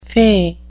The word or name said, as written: fey